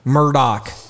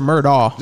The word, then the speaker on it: Murdaugh: Is it Murdoch or is it Murdaugh? – They just spell it weird with a GH at the end. It's weird. It's Murdoch. – Oh, it is? Doc? Like with a K? – Yeah, but it's It doesn't. It's not spelled. It's typical of the English language where it doesn't look anything like it's pronounced. Right. But I believe it's Murdoch. – It is Murdoch.